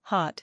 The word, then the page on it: Hot: electrical